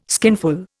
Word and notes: skinful: Indian

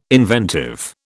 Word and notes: inventive: British English

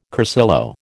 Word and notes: cursillo: American English

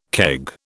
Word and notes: keg: British English

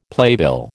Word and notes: playbill: American English

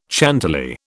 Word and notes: Chantilly: British English

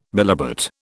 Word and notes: bilobate: British English

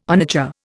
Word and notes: onager: Indian

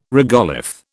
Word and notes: regolith: British English

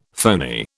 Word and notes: phoney: British English

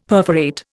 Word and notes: perforate: Indian

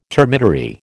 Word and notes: termitary: American English

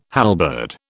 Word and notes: halberd: British English

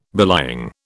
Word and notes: belying: British English

